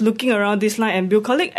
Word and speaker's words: Bucolic: Bucolic.